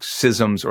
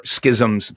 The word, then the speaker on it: schism: Yeah, so there's kind of two big schisms or schisms. Apparently pronouncing it both ways is correct.